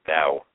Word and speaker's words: Tao: So that is, it's spelled T-A-O, by the way. I believe it's pronounced the Tao.